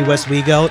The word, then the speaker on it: Westwego: Again, Katya all the way from Wesco. Is that how are we pronouncing that? – It was probably Westwego. It might have been a little bit of a typo there.